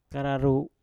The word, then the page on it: kararu: Mpur